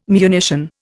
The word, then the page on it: munition: Indian